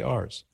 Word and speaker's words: Ars: Yes, I know Ars is how you'd pronounce it in French, but we're not in France. We don't say Paris, we say Paris. So I'll say Ars.